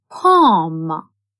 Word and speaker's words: palm: L is a silent letter in palm.